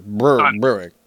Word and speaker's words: Berwick: Can I call it Berwick? Because it should be called Berwick. – Yeah, you got to say it properly. Berwick.